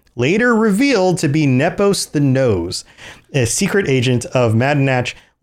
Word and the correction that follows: Nose: Then Nose is pronounced Nows. – Nows.